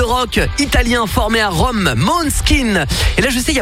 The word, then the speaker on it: Måneskin: Måneskin en danois veut dire clair de lune. Et il faut savoir aussi, petite subtilité, c'est qu'en danois, eh ben, le A se prononce O.